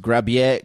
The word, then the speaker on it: Grabher: You see, Lauren is very proud of his last name, which may be pronounced Grabier. but it's spelled G-R-A-B-H-E-R, grab her.